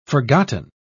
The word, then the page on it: forgotten: British English